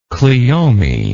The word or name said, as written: cleome